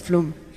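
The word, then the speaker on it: Flåm: Et c'est le dernier arrêt avant d'arriver à Flamme qui se prononce flôme.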